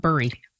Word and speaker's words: Bury: And by the way, if you're looking at it on the map, it's B-U-R-Y. It's pronounced Bury, right? Not Bury.